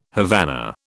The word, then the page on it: Havana: British English